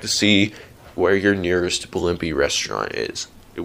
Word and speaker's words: Blimpie: Then, there's an actual submarine restaurant chain called Blimpy, except that it's not spelled B-L-I-M-P-Y, it's spelled B-L-I-M-P-I-E.